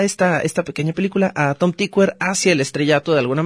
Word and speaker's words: Tykwer: Tom Tykwer, y sí, se pronuncia Tykwer, lo investigué porque es un apellido muy raro. La mayoría de la gente que conozco lo pronuncia como Tykwer, pero es Tykwer.